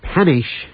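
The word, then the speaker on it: Panić: Yugoslav Premier Milan Panish has, I'm going to try to pronounce this right, because I'm always saying panic, and that's not that's how you spell it, P-A-N-I-C, but you say it Panish, or Panish, yeah, something like that.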